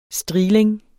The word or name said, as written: strigling